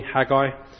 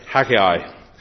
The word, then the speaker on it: Haggai: Well, when I got here this morning, there was some discussion how to pronounce the name Haggai, because apparently the North Americans and some people pronounce it wrongly, Haggai, and us English people pronounce it correctly, Haggai.